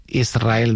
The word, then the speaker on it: Israel: Y la S con la R es difícil de pronunciar. La gente dice Israel, es verdad. – Que suene la S.